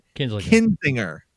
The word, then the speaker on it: Kinzinger: It's Republican Congressman Adam Kinzinger. – Kinzinger, yes. – Kinzinger. – Yes, okay. That's correct.